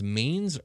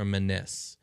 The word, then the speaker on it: manes: the equivalent to the lemurs, they become these things called, it's either pronounced manes or manes. It's M-A-N-E-S, but they're basically like abyssal lemurs. – Or manis.